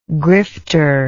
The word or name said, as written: grifter